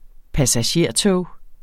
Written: passagertog

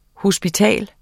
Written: HOSPITALET

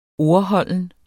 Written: ordholdende

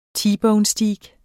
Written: T-bone-steak

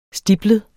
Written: stiplet

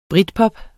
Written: britpop

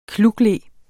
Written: klukle

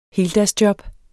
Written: heldagsjob

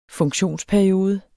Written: funktionsperiode

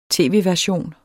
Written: tv-version

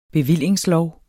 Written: bevilling

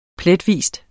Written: pletvis

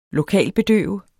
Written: lokalbedøve

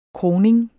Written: kroning

